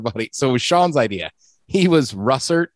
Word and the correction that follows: russet: I always say that wrong. Russert potatoes. – Russet. Yeah.